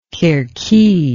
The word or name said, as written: kerki